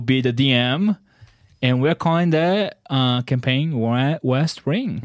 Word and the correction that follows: West Rim: West Rim, yeah. – West Rim, my bad.